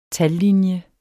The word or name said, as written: tallinje